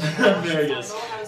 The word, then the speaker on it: Marigus: Marigus. – That's going to be annoying. That's going to be hard to remember. – Rhymes with asparagus. – Marigus.